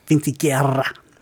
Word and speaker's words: Vinciquerra: Vinciquerra.